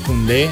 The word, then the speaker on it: Koundé: Los apellidos, todos terminan en E con acento en la E. – Ajá. – Koundé, Dembélé, Ousmane Doudé.